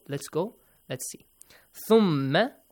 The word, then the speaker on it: ثُمَّ: If you have problem doing this, ثَ, the same as that, you got to bring your tongue out a little, okay? Bite it a little, not too much hard to hurt yourself, okay? ثَ. ثَ. ثَ. ثَ. ثَ. ثَ. ثُمَّ. It is the same as the pronunciation of TH in English that we have.